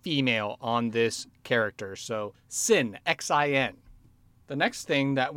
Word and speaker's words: Xin: Sin, which is spelled X-I-N. So it's actually a Chinese name and pronunciation.